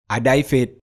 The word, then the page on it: Adaifet: Efik